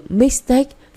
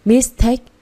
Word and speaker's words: mistake: Từ mistake phải nhấn vào take. – Nghe tôi đọc này. Mistake, không phải là mistake.